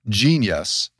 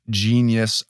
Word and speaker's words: jeen-yuhs: So really, really happy to have you talking about, this time, specifically Genius, a Kanye trilogy. And for those of you who, after hearing us, want to check this out on Netflix, it is spelled J-E-E-N-Y-U-H-S. So that's it, Genius, a Kanye trilogy.